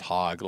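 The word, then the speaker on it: HAHWG: It's pronounced hog like the species or the animal.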